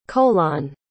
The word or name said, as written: coalan